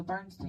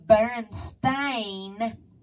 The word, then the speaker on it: Berenstain: Guess what, guys? How you going to spelling me? It's the Berenstain Bears. There's a motherfucking A in the end of it now.